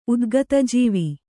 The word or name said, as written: ಉದ್ಗತಜೀವಿ